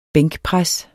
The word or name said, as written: bænkpres